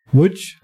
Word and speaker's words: Łódź: Ça, se prononce comment Łódź. Łódź Ouais. Ça s'écrit Lotz.